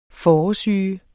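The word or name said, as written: Fåresyge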